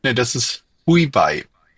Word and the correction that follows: Hubei: Ah, okay, nee, das war's nicht. – Also Hubei und jetzt dürft ihr mich alle dissen dafür, dass ich das nicht aussprechen kann.